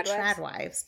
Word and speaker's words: trad wives: I would say trad wives because traditional. Yeah, trad wives.